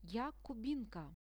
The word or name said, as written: ja kubinka